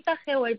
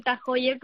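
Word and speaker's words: TAG Heuer: Haríais buen tándem Miguel Cabrera y tú eh, el español aquí representando a España con, este, con este magnífico reloj, que no sé si es Heuer o se dice en alemán Tajoyer. Eh, ¿Cómo, eh, ¿Cómo se dice exactamente? – Sí, bueno, en, en España solemos decir Tagewer, pero es verdad que cuando yo hice el evento el año pasado tenía que decir Tajoyer porque es realmente el nombre oficial.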